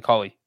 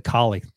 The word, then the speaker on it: Culley: David Culley. – Oh, you said it right that time. You said Collie the last three times.